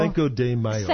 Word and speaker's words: Cinco de Mayo: Cinco de Mayo.